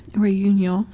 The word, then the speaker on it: Réunion: I guess that's how you say it, Réunion? – Réunion, yeah. – Réunion? Mm-hmm. Okay. – Réunion, but just look at Ray, yeah.